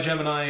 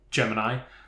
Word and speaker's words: Gemini: The constellation for which the project was named is commonly pronounced Gemini, with the last syllable rhyming with I. However, The staff of the Manned Spacecraft Center in Houston, and also the astronauts of the Gemini Project, they tended to pronounce it Gemini.